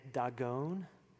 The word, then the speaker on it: Dagon: You know, in Hebrew, Dagon would be said Dagon.